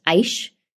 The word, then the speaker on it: Aish: I visited a place that I believe is pronounced Aish, A I S H.